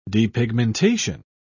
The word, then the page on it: depigmentation: British English